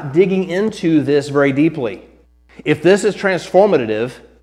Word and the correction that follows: transformative: I think I put an extra syllable on that word. If this is transformative, there we go, it's not going to transform you if you're not letting it impact your life, right?